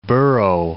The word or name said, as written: burrow